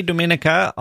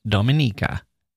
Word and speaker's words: Dominica: I say Dominica, although it's often pronounced Dominica, thanks to its long history as a French colony and the French name Dominique.